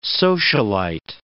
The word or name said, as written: socialite